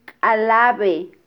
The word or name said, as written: kalabe